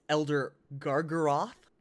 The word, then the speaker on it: Elder Gargaroth: Next one up is Elder Gargaroth, right? Yeah, I said that right? Yeah, okay, cool. He's shaking his head, yes.